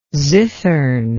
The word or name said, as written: zithern